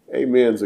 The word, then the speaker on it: amen: I told you one time it's actually pronounced in the Greek, amen. But that sounds funny because we've said it for so long, you know, amen, or a lot of people say amen. Amen is closer to the way it's pronounced, actually.